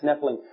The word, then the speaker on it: Nephilim: Notice that what the King James does is whenever the Hebrew word Nephilim, that if you take the Hebrew and just pronounce it, it's Nephilim.